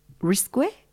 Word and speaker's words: risque: Qui ne risque rien n'a rien. Nothing ventured, nothing gained. – That's great. Say it again. – Qui ne risque? – Qui ne risque? Risque? Risque? – How do you spell it? – R-A-S-Q-U-E.